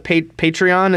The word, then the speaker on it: Patreon: So I guess where I'm going is, because you have a Patreon, is that how you say it? – Yeah.